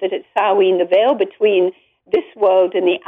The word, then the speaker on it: Samhain: Samhain pronounced, spelled S-A-M-H-A-I-N-E.